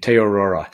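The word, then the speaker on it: Te Araroa: Yeah, that's a Maori name, so it's a tricky pronunciation. Te Aurora, and this is coast to coast, but this is north to south in New Zealand.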